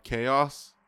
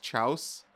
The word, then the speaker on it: chaos: Chaos. I kept pronouncing it as chouse.